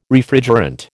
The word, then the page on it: Refrigerant: American English